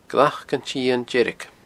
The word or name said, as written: glacantsitheindeirge